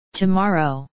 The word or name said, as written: tomorrow